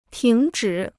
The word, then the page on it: 停止: tíng zhǐ